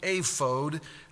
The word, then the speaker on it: ephod: So it's ephod, ephod. It's literally ephod, if you were to pronounce the Hebrew word. And this word is a transliteration of the Hebrew word. So really, honestly, it ought to be pronounced ephod, but we're not going to get real technical about that.